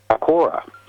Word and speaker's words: Aequorea: because the spelling is Aequorea, and Aquora is what I've learned is how it's actually spoken, but it doesn't look like that.